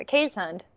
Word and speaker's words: Keeshond: And it was this dog, the Cajun. That's how I discovered this breed. Because it's spelled like Keyshawn, like K-E-E-S-H-O-N-D. But it's not fancy.